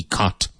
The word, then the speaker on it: cot: C-O-T, caught. And it's pronounced the same as C-A-U-G-H-T.